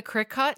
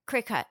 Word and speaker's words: Cricut: Are you familiar with the scrapbooking, the Cricut? – Yeah, Cricut. Cricut. – I think you pronounced it right. I think it's Cricut, but it's spelled Cricut.